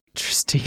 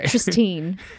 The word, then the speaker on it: Tristine: The only real deviation is that Tristan is played by a woman called, and I think it's pronounced Tristine. – Tristine. – It's like T-I-N-E, not T-E-N at the end. – Okay. – Tristine.